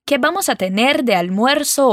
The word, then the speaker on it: almuerzo: Almuerzo. Bom, essa é uma palavrinha que alguns alunos sentem um pouquinho de dificuldade de pronunciar. Vamos por partes então. Al moer. So. Juntando tudo agora, almuerzo.